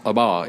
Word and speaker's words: Abba: It is pronounced Abba. It's Aramaic.